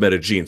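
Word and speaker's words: Medellín: Because I can tell you, if you go to Colombia, where the city is, by the way, it's pronounced Medellin.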